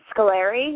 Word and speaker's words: Scolari: He says Scolari. He says, like, the end as in Larry.